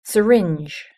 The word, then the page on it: syringe: British English